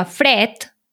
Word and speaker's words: fred: Per exemple, la paraula fred s'escriu amb mm. D final, però pronunciem... – Fred, sí, com si fos una T.